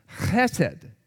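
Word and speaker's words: chesed (חֶסֶד): Now, in Hebrew, that is a word that is pronounced chesed.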